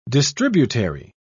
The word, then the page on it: distributary: British English